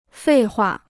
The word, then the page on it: 废话: fèi huà